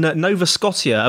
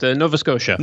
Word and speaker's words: Nova Scotia: And you're based up in Canada, in Nova Scotia. Have I said that correctly? – Almost. Nova Scotia.